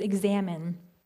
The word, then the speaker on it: examen: which is examine. Now, this one is the Latin word. It's spelled E-X-A-M-E-N. It's very similar to the verb examine that you've probably heard of, which is to like to dig into something, to understand something better, to search something.